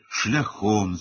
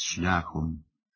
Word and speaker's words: шляхом: Зокрема, він каже «шляхом» замість «шляхом», «громадський» замість «громадський».